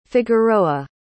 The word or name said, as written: figueroa